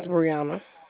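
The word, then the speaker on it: Breunna: My name is Brianna.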